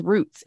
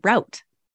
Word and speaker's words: route: But and that too, route. Everyone says roots. It's route. Rural route.